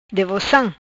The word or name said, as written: Devocam